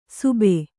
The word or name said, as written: ಸೂಬೆ